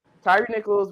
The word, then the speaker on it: Tyre Nichols: So, what ended up happening is Tyre Nichols was 29 years old.